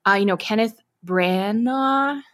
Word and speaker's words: Kenneth Branagh: It's by the same, Kenneth Branagh.